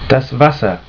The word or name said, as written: Wasser